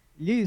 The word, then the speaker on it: gli: Gli spaghetti. – Li, like that? You don't yes. – You don't pronounce the G? – No, gli. It's a strange sound.